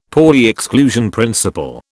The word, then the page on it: Pauli exclusion principle: British English